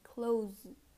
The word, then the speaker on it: clothes: So remember, it's really important to pronounce the z at the end, like close, close.